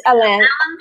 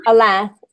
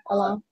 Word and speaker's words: Alain: It's Alain. Alain. In French, but you can call him Elaine. Yeah, Elaine.